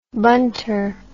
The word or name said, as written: bunter